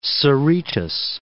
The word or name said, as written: Cerritos